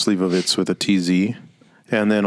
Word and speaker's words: Slivovitz: But that's the pronunciation is the Slivovitz. – Slivovitz. Yeah. So it's pronounced like it's a TZ at the end.